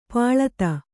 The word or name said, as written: ಪಳಿತ